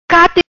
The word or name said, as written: Cate